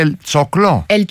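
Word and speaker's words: El Choclo: Αυτό είναι πώς προφέρεται Ελτσόκλο. – Ελτσόκλο. Και Άστορ Πιασόλα.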